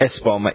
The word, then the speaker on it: Espoma: How do you spell that last part of it? Epoma, or is it? – Espoma. E-S-P-O-M-A.